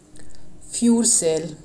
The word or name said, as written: Fjordsel